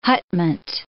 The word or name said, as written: hutment